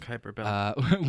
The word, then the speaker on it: Kuiper Belt: Kuiper Belt.